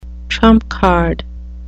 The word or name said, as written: trump card